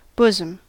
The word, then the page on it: bosom: American English